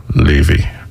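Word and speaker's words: Laufey: Even her name is spelled L-U-F-E-Y, it's pronounced Leify.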